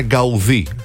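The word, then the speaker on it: Gaudí: Σαν σήμερα το 1926 έφυγε από τη ζωή ο Αντώνη Γκαουντί. – Τεράστιο. – Σωστή προφορά είναι Γκαουδί, σημαντικός Καταλανός αρχιτέκτον του μοντερνισμού.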